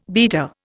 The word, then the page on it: beater: Indian